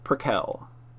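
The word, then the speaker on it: Prequelle: Oh man, is it not Prickell? – I've heard him pronounce it Prickell.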